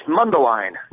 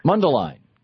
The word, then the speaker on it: Mundelein: Mundelein. How do you say that in Illinois there? – It's Mundelein. – Mundelein.